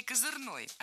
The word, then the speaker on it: козырной: В этом слове двойная норма ударения, и возможны оба варианта. С ударением на втором слоге и окончанием «ый» – козырный, и с ударным окончанием «ой» – козырной. И сленг здесь ни при чем, оба слова литературные.